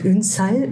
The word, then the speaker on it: Ünsal: Und Ünsal, da betonen wir Betonung ah, ja. liegt auf Sal.